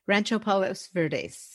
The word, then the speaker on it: Rancho Palos Verdes: Rancho Palos Verdes.